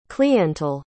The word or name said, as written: cliental